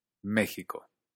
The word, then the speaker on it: México: México. México. Hay gente que pronuncia así, México, como en este país. Hay gente que pronuncia México con una H aspirada, sobre todo en las costas de Latinoamérica. Hay gente que pronuncia México con una aspirada ovular, como en partes de España. Todas variantes similares. Ji, ji, ji. Es decir, como si México se escribiera con J.